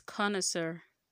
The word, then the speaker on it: connoisseur: So, what is the right pronunciation of connoisseur? It is connoisseur. Connoisseur. Okay, that's the one that the English girl pronunciation used to give me. Connoisseur. Sir. Not seer. Not connoisseur.